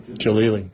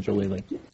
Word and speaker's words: Jalili: Maybe you should pick up the phone and call Mr. Jalani. Jalili. – Thank you. – Yeah. Pick up the phone, call Mr. Jalili.